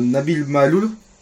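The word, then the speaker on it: Maâloul: Donc euh, Nabil Maloul, j'espère que je prononce bien, et désolé si je... – C'est comme ça. C'est comme, comme ça que ça voilà.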